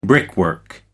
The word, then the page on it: brickwork: American English